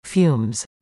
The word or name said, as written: fumes